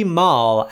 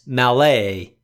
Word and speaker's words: Malle: He says, "I'm cringing every time I hear the host pronounce Louis Malle as Malay instead of Mall."